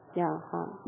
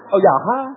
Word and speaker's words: 亚哈: How do you say his name in Chinese? – Yaha. – Yaba. – Yaha. – Yaba. Yaba. – Yaha. – Oh, Yaha. – Yaha. – English is Ahab.